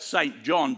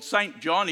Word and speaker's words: St. John: It's spelt St. John, but he didn't like to be called St. John. He said, I'm not a very saintly person, although most who knew him thought he was. So he preferred to pronounce it St. John, Harold St. John.